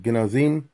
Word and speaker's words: Genazym: Genazim, which is spelled G E N A Z Y M.